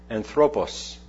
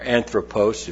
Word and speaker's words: anthropos: All nations of men, and the word men is from the Greek word anthropos, or anthropos, if you want to pronounce it with more of an English bent.